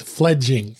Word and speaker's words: Fledging: As you heard, I fudged up their name. I called it Fledgling. I don't know why, but it is spelled F-E-F-L-E-D-G-I-N-G. So it's Fledging.